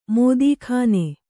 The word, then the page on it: ಮೋದೀಖಾನೆ: kannada